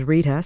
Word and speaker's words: Rita: My name is Rita, spelled R-I-T-A, and my last name, Rochelle, is spelled R-O-C-H-E-L-L-E.